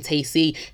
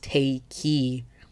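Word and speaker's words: Tayc: Yeah, well, basically, his name is not pronounced Tacy, it's pronounced Takey.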